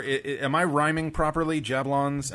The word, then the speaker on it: Jablons: Jablons. Jab rhymes with cab, and Lons rhymes with Fonz.